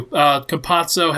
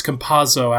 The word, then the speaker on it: Campazzo: Campazzo has been, or it's Campazzo actually. I mispronounced.